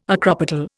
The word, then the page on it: acropetal: Indian